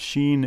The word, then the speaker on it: Chine: And Sheen is spelled C-H-I-N-E, and it's suggested in the story that Sheen is sort of like a future abbreviation for machine.